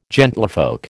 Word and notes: gentlefolk: American English

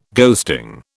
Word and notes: ghosting: British English

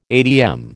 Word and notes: atm: American English

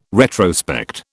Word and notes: retrospect: British English

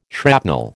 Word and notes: shrapnel: American English

